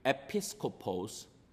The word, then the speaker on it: episkopos: The word that is translated in our passage as overseers is a word that is pronounced in Greek, episkopos. And episkopos, it is a side of a leadership that stresses on the oversight, meaning, is this church being fed the right gospel?